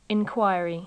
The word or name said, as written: inquiry